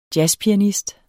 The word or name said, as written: jazzpianist